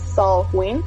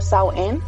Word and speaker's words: Samhain: Pero el verdadero origen de Halloween lo encontramos en el festival celta que se llama Samhain, o como aquí se dice que lo pronuncian um, Samhain o Samhain.